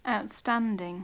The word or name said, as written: outstanding